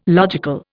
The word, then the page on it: logical: Indian